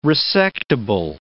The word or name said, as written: resectable